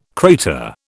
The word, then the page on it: crater: British English